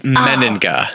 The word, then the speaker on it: Mennenga: Oh, that's like the most common mispronunciation. It's Mennenga.